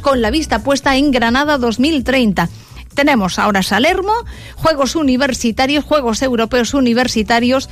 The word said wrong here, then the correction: Salerno: que se celebra en Salermo, pero que luego Salerno, luego nos quedaría Croacia, lo he dicho bien, ¿no, Enrique?